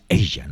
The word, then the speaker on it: Aja: Sí, el Aja. Bueno, no, no es Aja, me enteré hace poco. Es ella, ¿no? Se pronuncia ella y vamos a escucharlo precisamente muy bien pronunciado por los mismos miembros de la formación.